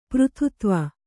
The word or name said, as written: ಪುರಾತತ್ವ